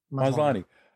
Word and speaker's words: Maslany: Maslany should play her. – Maslany.